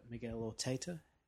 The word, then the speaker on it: Miguel Arteta: It's a film directed by Miguel Arteta.